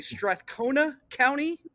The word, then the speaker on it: Strathcona County: You're from, I'm going to try to say this correctly, Strathcona County. – Yeah. – Did I say that right? – Yeah, exactly.